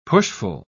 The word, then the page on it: pushful: British English